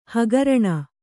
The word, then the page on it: ಹಗರಣೆ: hagaraṇe